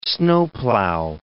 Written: snowplow

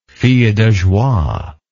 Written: fille de joie

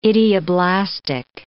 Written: idioblastic

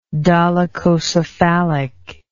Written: dolichocephalism

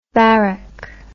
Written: varec

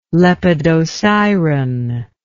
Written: Lepidosiren

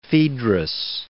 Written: Phaedrus

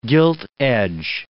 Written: gilt-edge

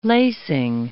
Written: lacing

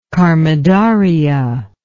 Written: karmadharaya

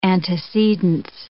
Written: antecedence

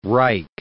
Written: Reich

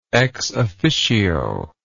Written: ex officio

